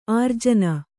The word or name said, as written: ಆರ್ಜನ